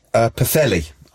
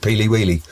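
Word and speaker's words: Pwllheli: We were in Pathelli. I can't say it right because I always call it Peely Wheely because that's how it's spelt.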